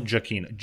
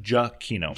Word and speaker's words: Giacchino: It's Michael Giacchino. Giacchino. – Giacchino.